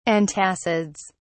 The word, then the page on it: antacids: British English